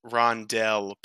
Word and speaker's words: rondelle: But oh, it is pronounced rondel because it's usually spelled R-O-N-D-E-L-L-E.